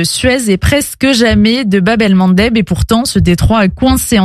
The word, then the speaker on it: Bab el-Mandeb: Je vais commencer par le nom que vous prononcez très bien, Elsa, d'ailleurs, Bab el-Mandeb.